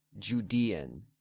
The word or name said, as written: Judean